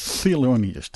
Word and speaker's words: Thelonious: De meester zelf, Thelonious Monk. Moet je zo heel goed uitspreken. Thelonious, dat. Ja, dat.